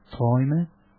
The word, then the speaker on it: Träume: La canzone, io il tedesco non lo so, comunque provo a dirlo lo stesso, si chiama Träume, vuol dire sogni, e l'ho detto bene. – Perfetto, anche l'umlaut, cioè il plurale, perfetto.